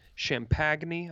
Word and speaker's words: Champagne: Papa went out to the Kroger's, got himself a nice $9.99 bottle of Champagne, I believe it's pronounced. – It is. That is perfect pronunciation.